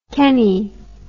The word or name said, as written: Kennie